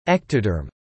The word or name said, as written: ectoderm